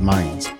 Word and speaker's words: Mainz: Today we're going to hear about the weather in Mainz, Germany, or I think it's pronounced Mainz.